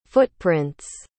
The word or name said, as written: footprints